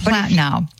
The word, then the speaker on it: Platinow: Her last name, very difficult to pronounce. Poletino? – Yeah, it's Platinow.